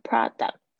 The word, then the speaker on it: product: 注意一下 product 这个 kt 很轻的 product work。 How does this product work？啊，所以你不要硬要念成 product，就很口音太重。